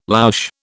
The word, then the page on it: louche: British English